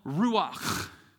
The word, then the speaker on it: ruach: this Hebrew word called ruach. You got to say it like a kernel of popcorn. It's stuck in the back of your throat. Ruach.